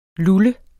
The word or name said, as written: LULLET